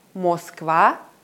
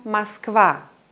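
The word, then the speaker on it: Москва: Beim Wort Moskwa ist die Betonung auf dem A in der letzten Silbe. Deswegen wird dieses A deutlich ausgesprochen. Das O in der ersten Silbe ist unbetont und wird zu einem reduzierten A. Ich sage also nicht Moskwa, sondern Moskwa.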